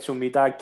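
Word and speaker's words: Middag: Is it Hiddag? You pronounce it or Midag? Midag.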